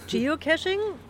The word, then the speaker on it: Geocaching: Geocaching, habe ich das jetzt richtig ausgesprochen? – Ja, Geocaching.